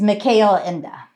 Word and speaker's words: Michael Ende: So again, as you said, it was based on the German book by Michael Ende, and how do you pronounce correctly? – It's Mikhail Ende.